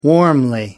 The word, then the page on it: warmly: British English